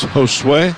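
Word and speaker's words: Josué: Don't pronounce the J, so it's Josue, right? – Mm-hmm.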